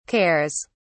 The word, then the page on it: cares: British English